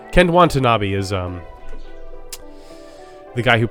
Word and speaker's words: Watanabe: Um, and then Ken Wanta being rude to other ants. Wanta not being rude. Sorry, I messed that up.